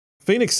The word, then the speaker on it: Phenix: If you're not familiar with the story about Phoenix City, Alabama, and by the way, for those of you that aren't familiar, Phoenix is spelled P-H-E-N-I-X. Phoenix City, Alabama, down yonder by Auburn, near the Georgia line.